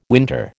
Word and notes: winter: American English

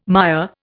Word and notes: Maya: Indian

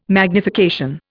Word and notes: magnification: Indian

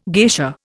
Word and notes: geisha: Indian

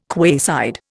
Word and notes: quayside: Indian

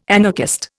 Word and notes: anarchist: Indian

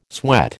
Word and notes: sweat: American English

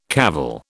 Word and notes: cavil: British English

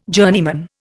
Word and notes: journeyman: Indian

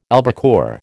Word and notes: albacore: American English

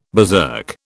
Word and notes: berserk: British English

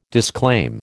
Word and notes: disclaim: American English

